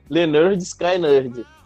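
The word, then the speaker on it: Lynyrd Skynyrd: Meu quarto lugar, cara, vai para Simple Man do Leonard Skyline. Isso a pronúncia se diz essa no álbum, né? Que o nome do álbum é Se Pronuncia Leonard Sky Nerd. O nome do álbum da banda é esse.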